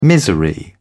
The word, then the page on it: misery: British English